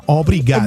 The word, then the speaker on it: obrigado: Porque van a pensar que estás diciendo obligado. – No, obrigado.